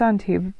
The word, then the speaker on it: Antibes: I mean, the I I stayed in Cannes and I did a day trip to Nice and to Antibes, or uh, it's Antibes,